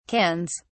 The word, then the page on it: cans: British English